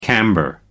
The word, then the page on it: camber: American English